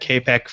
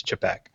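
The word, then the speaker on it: Čapek: And it turns out that I've been pronouncing in my head Capek for so long, and that's how I still pronounce it in my head. But the pr- proper, real pronunciation is Chapek.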